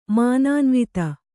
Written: ಮಾನಾನ್ವಿತೆ

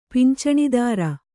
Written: ಪಿಂಚಣಿದಾರ

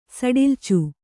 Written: ಸಡಿಲ್ಚು

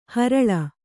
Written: ಹರಳೆ

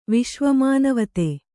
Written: ವಿಶ್ವಮಾನವತೆ

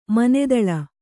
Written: ಮೋನದಳೆ

